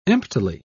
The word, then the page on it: emptily: British English